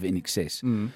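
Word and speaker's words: INXS: Oh, no, you know Roberto, our sales manager, our boss, who accused me of singing over the top of NXS.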